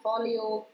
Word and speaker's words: Forleo: Ist eine Italienerin, also im Grunde schon, wie du es sprichst, Marie mit E und Folio, ganz genauso wie du es aussprichst, F-O-R-L-E-O.